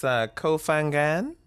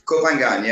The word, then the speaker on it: Koh Phangan: It's Koh Phangan. – Koh Phangan.